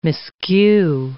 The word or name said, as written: miscue